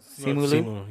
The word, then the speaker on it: Simu: You know, like Simu. Oh my God, is that how you say his name? Or is it Simu? – Yeah. Simu.